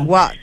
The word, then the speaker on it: WOT: ¿Cómo lo pronunciamos ¿Qué tal? ¿Cómo estás? – What? – What? Sí, tenemos que hablar de What.